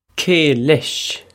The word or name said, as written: cé leis